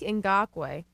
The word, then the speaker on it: Ngakoue: Yannick Ngakoue.